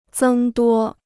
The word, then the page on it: 增多: zēng duō